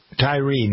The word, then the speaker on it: Tyre: This business in Memphis, this whole business about the murder of Tyree, is it, I'm not sure I've been pronounced, Tyree or Tyre? Tyree Nichols.